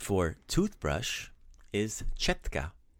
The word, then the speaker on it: četka: But četka rhymes with the Croatian word for ant, tetka.